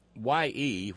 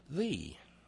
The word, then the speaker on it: ye: Because, in fact, as Mark Twain pointed out brilliantly, Y-E, which is often pronounced Ye by people who don't know better, in Elizabethan English is actually pronounced thee. That Y was a hard T-H.